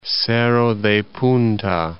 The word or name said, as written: Cerro de Punta